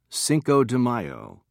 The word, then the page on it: cinco de mayo: American English